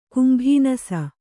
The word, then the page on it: ಕುಂಭಾನಸ: kumbhānasa